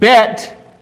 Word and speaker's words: bet: So now, the letter bet, and that's how you say it, bet, means house. And even if you speak in Hebrew, you're going to use the word bet.